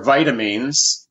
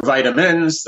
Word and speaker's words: vitamin: Vitamins, originally, they were called vital amines or vitamines, the way it's pronounced in the UK, or vitamins, the way the Yankees pronounce it.